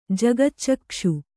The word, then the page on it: ಜಗಚ್ಚಕ್ಕ್ಷು: kannada